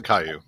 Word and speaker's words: Caillou: You're supposed to pronounce it Caillou.